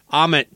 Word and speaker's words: Ahmet: And I think after that that last performance they did, which was like for Ermit, oh God, I'm going to butcher his name. – Ahmet, yeah, the big time music guy behind them and all that stuff from the label.